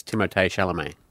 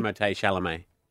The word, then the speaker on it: Timothée Chalamet: He's French. So it's Timothée Chalamet. But he doesn't care how you say it. Timothée Chalamet.